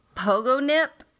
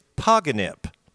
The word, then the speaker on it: Pogonip: It's Poganip. – Oh, I like Pogo Nip better. – Yeah, it's spelled Pogonip, just like it sounds. Pogo Nip, but it's pronounced Poganip. So, Poganip. And so happens when you jump on a pogo stick.